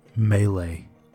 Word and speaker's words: Melee: It's actually pronounced Melee. – Melee? – Yes, sir. – But M-E-L-E. There's E's in it.